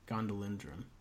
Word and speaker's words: Gondolindrim: But he's pretty successful among the Gondolindrim. That's how they say it, plural, I guess. Gondolindrim.